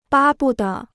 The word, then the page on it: 巴不得: bā bu de